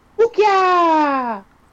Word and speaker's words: Okja: Wookia! – Go ahead and spell it. – Yeah. – O-K-J-A. – Yeah. – O-K-J-A.